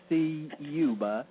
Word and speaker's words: Ciuba: Of course, if you Google my name, if you spell it correctly, you hit every letter in the last name, Ciuba. So it's Ted, of course, C-I-U-B, as in Bravo, A.